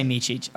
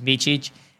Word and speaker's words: Michic: So it's really easy to say Michic, okay? Because it's like the C's just have the little ch on them. They just don't have it in English like that. So that's how you pronounce my name is Michic.